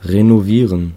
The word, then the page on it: renovieren: /ʁenoˈviːʁən/